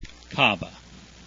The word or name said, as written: cava